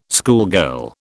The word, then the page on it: schoolgirl: British English